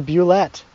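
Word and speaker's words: Bulette: But Wikipedia says Boulet. – Yeah, well, that's stupid. It's only pronounced Boulet because Gary Gygax said so.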